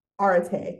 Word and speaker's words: arete: Tell us about arete. Am I pronouncing it right? Arete. – You got it.